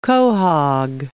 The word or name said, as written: quahog